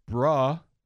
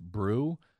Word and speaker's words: Bru: Super genius Mark Bruh. It's spelled B-R-U, so it's probably pronounced Brew, but I'm going to go with Bruh.